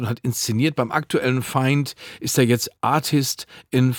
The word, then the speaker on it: FIND: Und dann fällt mir Holzkopf aber nicht ein, dass das Festival, das da gerade an der Schaubühne vor sich hin tobt, dass das nicht Find, sondern natürlich Feind ausgesprochen wird, weil es ja ein internationales Ding ist, international als Theater zu sehen ist.